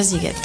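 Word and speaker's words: Sziget: Se escribe S-Z-I-G-E-T y se pronuncia Siget.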